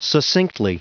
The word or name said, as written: succinctly